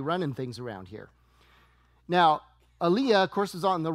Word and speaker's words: Elijah: In fact, I have a cousin named Elijah, and we call him Lige for short.